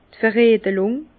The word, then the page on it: d' Veredlùng: Bas-Rhin; Herrlisheim